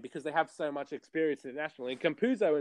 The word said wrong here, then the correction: Campazzo: in particular, or Campazzo rather, I think I pronounced it wrong, Campazzo, he is an absolute magician with the basketball in terms of passing.